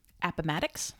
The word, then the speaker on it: Appomattox: Appomattox.